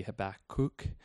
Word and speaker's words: Habakkuk: You might have heard it different ways. I think the correct way is more like Habakkuk. So maybe Habakkuk might be the best way to say it.